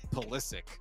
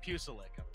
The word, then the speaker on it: Pulisic: Pulisic. I'll get his name right eventually. – Pusilic.